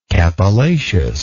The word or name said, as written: capillaceous